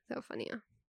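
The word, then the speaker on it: Theofania: So that's my middle name, Theophania. – How do you spell that? – T-H-E-O, so like Theo, then F-A-N-I-A. – Theophania. – Yeah. – But you pronounce it completely different.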